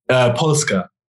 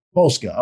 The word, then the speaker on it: Polska: How do you say it when it's on the front of the jersey? – Polska. – Polska.